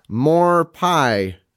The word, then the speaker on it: MOARPYE: Jonathan Solis, More Pie. And that is not spelled like More Pie. It's spelled MOARPYE.